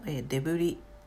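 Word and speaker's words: debris: それから、デブリ、これも、デブリ、瓦礫や破片のことですね。この単語は最後に S がついてるんですが、この S は発音しません。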